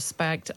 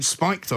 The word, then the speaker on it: spiked: Brendan O'Neill is editor of Spagged Online. – Spagged Online? – As is spaghetti bolognese online. – Oh, yes, right. Does she mean spiked online? – Yes.